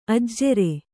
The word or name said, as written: ಅಜುರ